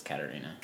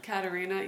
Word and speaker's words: Katharina: Is it Katharina or Katrina? – I think it's spelled Katharina, but it's Katharina. – Katharina. Yeah.